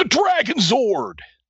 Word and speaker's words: Dragon Zord: But once it did, and it was truly impressive, and it is my number one, the Dragon Zord. You have to say it like that because that's how Tommy said it.